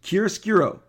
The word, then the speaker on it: Chiaroscuro: And Chiaroscuro is C-H-I-A-R-U-S-C-U-R-O. It's very hard to pronounce. Chiaroscuro, okay?